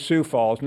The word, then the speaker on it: Sioux Falls: Just to get the pronunciation correctly, it is Sioux Falls, not Sioux Falls.